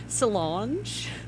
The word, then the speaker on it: Solange: Oh, you would always put a, I'm gonna butcher her name, Salon, Beyonce's sister. – Oh, I know who you're talking about. – Solange. – Solange, yeah. – Is that how you say it? Solange.